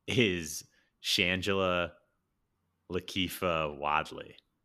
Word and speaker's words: Shangela: And yeah, in real life, she's played by well, her name that she goes by is Shangela. I think that's how you say it. Shangela. – I think Shangela.